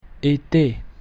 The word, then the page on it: ete: French